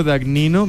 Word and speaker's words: Dagnino: Así es, Francisco Dagnino. Mi apellido se escribe D-A-G-N-I-N-O, D-A-G-N-I-N-O.